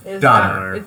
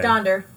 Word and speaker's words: Donder: Is it Donner or Donder? – Donner. – No, okay. It's Donder. Donder. Well. Yeah, I'm serious. – Hmm. Yeah. – Okay. They've been pronouncing it wrong. Yeah, it's Donder.